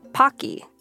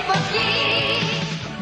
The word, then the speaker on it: Pocky: Think about the last time you might have eaten a box of pokki, or pocky, as it's pronounced in the West. Okay, pokki. The name pokki mimics the Japanese onomatopoetic word for snapping, pokki pokki.